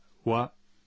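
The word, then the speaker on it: wa (は): Se pronuncia wa, pero se escribe ja.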